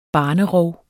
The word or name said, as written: barnerov